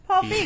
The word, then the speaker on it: Feig: So we have A Simple Favor by Paul Reig. I can't even pronounce it. – Paul Feig.